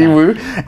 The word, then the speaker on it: Yiwu: Okay, Yiwu.